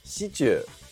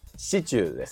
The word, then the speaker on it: シチュー: シチュー」と発音しますシチューですねえー、死にアクセントが来るものの、中の部分で上がってしまうんですよね。